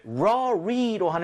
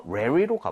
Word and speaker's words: library: 자, library가 있는데, 얘는 사실 rory로 하는 사람도 있고요. 발음을 예. – rary로 하는 사람들도 있는데요. 저는 개인적으로는 좀 rary에 가깝게 하는 편이기는 해요.